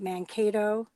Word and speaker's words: Mankato: And even just learning about things like what happened, and correct me if I'm wrong, the massacre at Mankato, am I saying it right? – Yep.